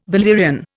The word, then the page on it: valerian: Indian